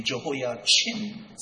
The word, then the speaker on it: Jehoiachin: It is being pronounced in two different ways, but our pronounce his name Jehoiachin, C-H-I-N, and Zedekiah.